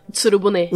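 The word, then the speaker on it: Utsuro-bune: Utsuru Buni. Como fala? Utsuru Bune. Utsuru desculpa, eu sabia que eu tava falando errado.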